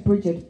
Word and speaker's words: Brigid: But my name is spelled Bridget, B-R-I-G-I-D, and that's taken from my grandmother and my great-grandmother.